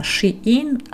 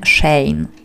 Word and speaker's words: Shein: To jest właśnie poprzednik Shein, stąd właśnie ta sugerowana wymowa Shein, a nie Shane, ale mniejsza o to.